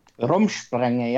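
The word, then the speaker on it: Rumspringa: And we find out that Jacob is on Rumspringer. Is that how you say it? – Rumspringer, yeah.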